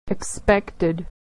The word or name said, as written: expected